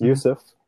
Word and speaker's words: Józef: It's pronounced Yusuf originally. Very similar to kind of the Middle Eastern Yusuf in pronunciation.